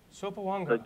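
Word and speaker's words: Sopoaga: You pronounced the name wrong, but, How do you pronounce it? – Sopoanga. – Sopoanga. – The G's are silent. Sopoanga.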